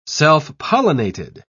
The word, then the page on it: self-pollinated: British English